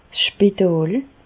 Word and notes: d' Spitàl: Bas Rhin; Reichshoffen

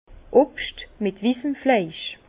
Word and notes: Obscht mit wissen Flaisch: Bas Rhin; Herrlisheim

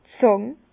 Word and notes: d' Zàng: Bas-Rhin; Herrlisheim

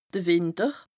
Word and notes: de Wìnter: Bas-Rhin; Strasbourg

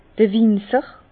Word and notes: d'r Winzer: Bas Rhin; Herrlisheim

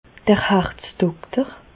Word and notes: d'r Harzdokter: Bas Rhin; Reichshoffen

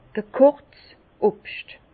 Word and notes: gekochts Obscht: Bas Rhin; Herrlisheim

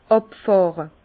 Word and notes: àbfàhre: Bas-Rhin; Herrlisheim

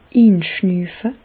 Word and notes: inschnüüfe: Bas-Rhin; Reichshoffen

